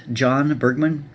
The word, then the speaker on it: Jon Bergmann: You can follow me on Twitter at Jon Bergmann.